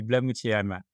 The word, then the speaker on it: Blamoutier: Exactly, Blamoutier. As you can hear, I'm French.